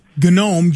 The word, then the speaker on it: GNOME: GNOME, G-N-O-M-E. Yes, and in Linux, they pronounce the G.